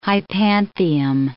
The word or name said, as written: hypanthium